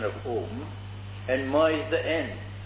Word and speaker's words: Om: And because the whole language is included, all Krishna's names are included in om. A, u, ma. Om. See how I pronounce. Om. This is vibration.